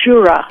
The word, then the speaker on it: Jura: And tell us how to pronounce it correctly. Is it Jura? – It's Jura.